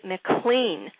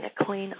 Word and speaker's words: McLean: Ciora, the name of the track, and with him on that, bass, Larry Ridley, Herbie Hancock on piano, Billy Higgins on drums, Jackie McLean, that's how the family pronounces the last name, Jackie McLean on sax.